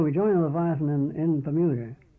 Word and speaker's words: Leviathan: I'm sorry, how do you pronounce it? – Leviathan.